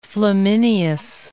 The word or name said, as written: Flaminius